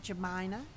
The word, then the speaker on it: Jamina: I did until the sixth grade, and then we didn't have phonetics back then, so everybody pronounced it Jamina. It's J-A-M-I-N-A, and my pediatrician called me Aunt Jemima.